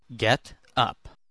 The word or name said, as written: get up